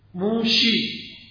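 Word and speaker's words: موشی (משה): شما میگید تو فارسی و عربی موسا، عبریه میگه چی؟ موشی. سینشو چین میکنه. موسا را به زبان عبری نمیگه موسا، میگه موشی.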